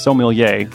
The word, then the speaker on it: sommelier: Also, it's pronounced sommelier, not whatever I said yesterday.